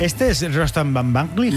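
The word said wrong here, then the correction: Batmanglij: Batmanglig, creo que es ese.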